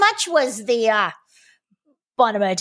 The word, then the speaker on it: Bonomo: You spell it B-O-N-O-M-O, Bonomo, oh-oh, Bonomo, Turkish Taffy.